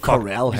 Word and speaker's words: Carell: Vi siger jo Carell her i studiet, sådan som du rigtig skal udtale det.